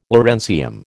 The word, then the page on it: lawrencium: American English